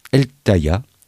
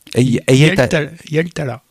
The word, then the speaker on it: Hietala: Et suivi de Marco Eltaya. – Yeltala.